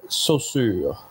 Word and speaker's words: Saussure: It's Saussure. – Yeah, that's the French way of saying it. – Ferdinand de Saussure. – Exactly. – You have to say it like you ate an oyster and it disagreed with you.